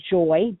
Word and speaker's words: Joye: And then if you would like to be a Facebook friend of mine, you can go to Joy, J-O-Y-E. The last name is Pugh. It's spelled P as in Paul, U-G-H.